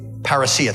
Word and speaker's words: parousia: Parousia. Thank you. Parousia, the Koine Greek. You pronounce it parousia, caught up.